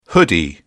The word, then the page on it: hoodie: British English